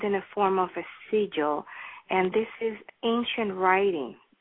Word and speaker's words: sigil: And then I would also like to talk about, and I hope I'm saying it right, the signal? – Sigil. – Sigil, okay. – Sigil, S-I-G-I-L, sigil, yes.